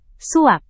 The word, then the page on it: suap: Indonesian